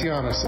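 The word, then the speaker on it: Llanes: Louis Yannis, L-O-U-I-S, L-L-A-N-E-S.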